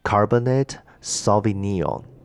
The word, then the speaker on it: Cabernet Sauvignon: 然后像是其他的一些 merlot 梅洛，然后哎，这个有一个东西叫做 Cabernet Sauvignon。